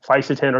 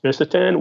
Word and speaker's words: fisetin: I think fisetin, or sometimes pronounced visetin, would be, you know, second, but not quite really even a close second.